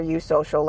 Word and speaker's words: eusocial: Eusocial is spelled E-U-S-O-C-I-A-L. But I like to think of it as one bee asking another, eusocial, and the eusocial bee being like, yeah.